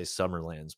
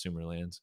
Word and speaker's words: Sumerlands: Eternal Champion and Sumerlands, I believe it's pronounced like Sumeria, you know. I used to always say Summerlands, but I think it's Sumerlands.